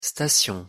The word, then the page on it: station: French